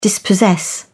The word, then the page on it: dispossess: British English